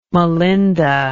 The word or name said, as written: melinda